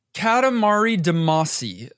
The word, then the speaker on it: Katamari Damacy: Katamari Damacy. Mispronouncing that, I'm sure. – No, you're good. That's it.